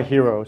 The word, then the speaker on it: Hiro: I just go by Hiro.